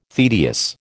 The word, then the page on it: tedious: American English